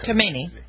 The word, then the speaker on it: Khamenei: Khamenei.